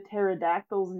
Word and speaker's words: pterodactyl: So I will tell you, it kind of blew my mind when I finally read Terry the Pterodactyl's name written out, and I was like, wait, it has a silent P just like pterodactyl?